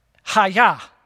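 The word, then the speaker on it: haya (היה): So the really cool thing, just real quick, little side note for you, "I am" in the Hebrew is actually pronounced "haya." Like, okay, so think karate. Literally, think of like, and here's the thing, you don't say "I am" with like, you're not like, "Hiya."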